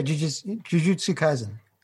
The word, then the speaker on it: Jujutsu Kaisen: And Jujutsu Kaisen, is that how you pronounce it? – Yeah, Jujutsu Kaisen.